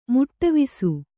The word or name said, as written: ಮಡಿವಾಸು